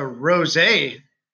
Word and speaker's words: rosé: And you went with the rosé. You went even extra. Oh, you pronounced it right.